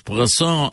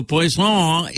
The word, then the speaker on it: poisson: Right, so poisson is spelled P-O-I-S-S-O-N. Right? So you better be very careful how you pronounce that word because he might just bring something that will kill you.